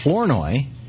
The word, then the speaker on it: Flournoy: And we have Michelle Flournoy. Am I pronouncing that right, Michelle? – Yes, you are.